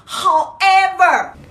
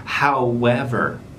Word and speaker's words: however: However，那么这个发音呢，如何从普通的发音向优秀的发音更进一步呢？中间要记住有一个半元音是 w，应该是 however，不是 however。